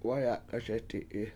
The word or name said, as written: wayAk7aSeti7i